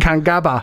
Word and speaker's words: Kangaba: Så ja, tror jeg, det udtales. Kangaba. – Du kigger på mig som om... Ret mig lige. – Kangaba.